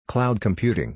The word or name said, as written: Cloud Computing